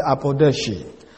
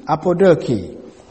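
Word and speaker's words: apodoche (ἀποδοχή): The word acceptance comes from the Greek word apodeke. I may pronounce it apodoche. A-P-O-D-O-C-H-E. Apodoke. It means acceptance, with the connotation, of course, of approval and appreciation.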